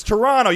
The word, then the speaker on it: Toronto: It's Toronto. You don't pronounce the T.